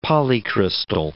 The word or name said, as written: polycrystal